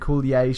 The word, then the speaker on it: Coulier: Where he talks about Dave Coulier. Is that how you say it? – Dave Coulier.